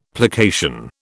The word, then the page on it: plication: British English